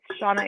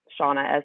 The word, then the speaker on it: Seana: But like, if you go on YouTube and Google, it's Seana AC, the way my name is spelled, Seana, S-E-A-N-A, A-C.